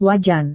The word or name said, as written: wajan